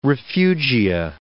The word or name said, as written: refugia